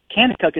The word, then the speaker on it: Kanakuk: Kanakuk is spelled K-A-N-A-K-U-K.